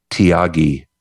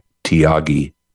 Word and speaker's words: Thiagi: There is a guy named Tyagi. He's an Indian man. His name is spelled T-H-I-A-G-I, Tyagi.